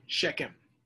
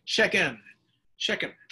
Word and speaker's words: Shechem: His concubine, who lived in Shechem, that's going to be the setting of our text tonight, Shechem, okay? I'll just pronounce it that way so I don't coat the screen with my phlegm. Shechem.